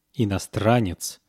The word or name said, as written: inostranets